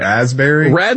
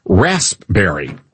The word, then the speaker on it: raspberry: Raspberry? – No, that's not how you say it. It's rasp. Raspberry. – Oh yeah, there's a P there.